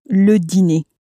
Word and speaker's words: le dîner: Again, make sure when you're saying the dinner in French that you pronounce it as le dîner. – That sort of A sound at the end of it.